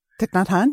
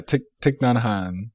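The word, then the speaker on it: Thích Nhất Hạnh: I'll say the biggest change and the most intention came when I started reading, I always mispronounce his name, Thich Nhat Hanh. – Thich Nhat Hanh, yeah. – Yeah, Thich Nhat Hanh. – I mean, that's the American version. I don't know what the true pronunciation right.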